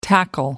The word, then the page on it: tackle: American English